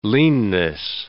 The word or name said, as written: leanness